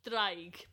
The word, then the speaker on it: Draig: Draig. But it's spelled D-R. – Draig. – Yeah, you got to roll the D.